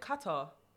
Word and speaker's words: Qatar: So it's right. Like pronounced K-A-T-T-E-R. Qatar. Phonetically, yes.